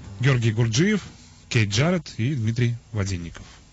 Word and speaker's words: Гурджиев: Фамилия у него все-таки Гурджиев. – Гурджиев? Мне почему-то, да. Гурджиев. Слава богу, что вы проверили это. Потому что я мучаюсь уже года 4. – Ударение на «и», и он оставил после себя 6 композиций, угу, которые длятся от 2 минут до 25.